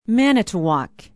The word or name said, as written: manitowoc